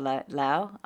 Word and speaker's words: Laos: you know, I've looked at Laos. I'm not saying that right, am I? Laos? – But you're saying it a lot better than Robbie would say it. – I think it's Lao, actually.